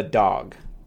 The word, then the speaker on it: DAWG: But it's spelled T-R-I-E to disambiguate. And the variant of it is called a dog, which is D-A-W-G.